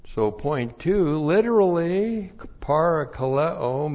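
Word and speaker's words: parakaleo (παρακαλέω): Because of this, 2 Corinthians 7, 11 and 12, on account of this, for this reason, I am parakaleoed. Paul is parakaleoed. P-A-R-A-K-A-L-E, long O, parakaleo.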